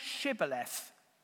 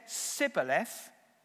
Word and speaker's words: Shibboleth: If he replied no, because he doesn't want to get killed, they said, all right, say Shibboleth. If he said Sibboleth, because he couldn't pronounce the word correctly, they seized him and killed him at the fords of the Jordan. What it means is that the Ephraimites couldn't say shh.